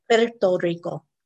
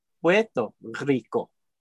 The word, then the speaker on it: Puerto Rico: Did I say that right? – Puerto Rico. – Oh, Puerto Rico. – There you go. That's close enough.